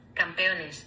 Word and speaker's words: campeones: Hey Siri, how do you say champions in Spanish? – In Spanish, champions is campeones.